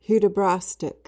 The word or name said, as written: hudibrastic